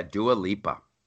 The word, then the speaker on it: Dua Lipa: And first of all, you have to tell me, is her name Dua Lipa? Is that the correct way to pronounce it? – Oh, my gosh. Yeah, Dua Lipa.